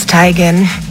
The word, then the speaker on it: Teigen: And her mom confirmed that we've been saying her name wrong this whole time. – I'm tired of living this lie. It's Tigan. Isn't it, Mom? – Yep. – I told you, it's Tigan.